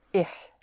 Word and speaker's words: ich: And I want to make sure right from the beginning of how to pronounce a sound which we don't have in English. And that is the sound of the ch, ich. I is spelled I-C-H, pronounced ich. It's a very soft sh sound. So instead of ish, make it ich, ich. That sound is more in the back of your mouth.